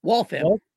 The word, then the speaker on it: Waltham: In Waltham, is that how you say it? – Waltham. – Waltham, okay. Waltham, Massachusetts.